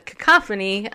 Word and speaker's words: cacophony: The sovereignty of America is at stake, but the cacophony of denial about... Or that's not right. How do you say that? – You did great. – Cacophony, I did it. It's a miracle. I said the word right.